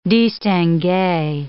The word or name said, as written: distingue